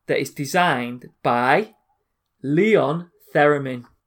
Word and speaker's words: Theremin: I think it's pronounced Theremin. – Theremin. – Mm. – Right. It's Theremin. – Anyway. – Right. – Go on. That's how you say the musical instrument anyway.